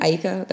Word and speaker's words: Aiko: Aiko.